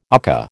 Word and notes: UCCA: American English

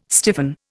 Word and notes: stiffen: Indian

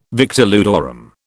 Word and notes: victor ludorum: British English

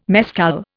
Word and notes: mescal: Indian

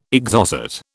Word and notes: Exocet: British English

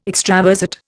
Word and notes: extravasate: Indian

